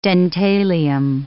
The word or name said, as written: dentalium